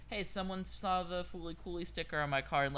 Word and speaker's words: Fooly Cooly: FLCL, pronounced as Fooly Cooly, is an anime series written by Yoji Enokido, directed by Kazuya Tsurumaki.